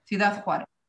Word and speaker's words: Ciudad Juárez: Ciudad Juárez.